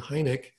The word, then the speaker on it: Heinecke: I just need to know how to spell my name, and I'll spell it Stu, S-T-U, and then Heinecke, H-E-I-N-E-C-K-E.